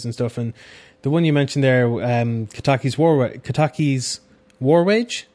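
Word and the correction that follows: Kataki, War's Wage: is that how you say it? Is it Kataki War's Wage, there we go.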